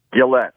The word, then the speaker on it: Gillette: What is the correct pronunciation? – Gillette, but I'm very much Gillette. Gillette. Is that French Canadian in background? – It is. That's correct.